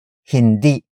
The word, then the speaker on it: hindi: First, we have saan, which is spelled as S-A-A-N. It means where, followed by hindi. Hindi. Hindi. Hindi is spelled as H-I-N-D-I.